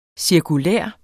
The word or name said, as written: cirkulære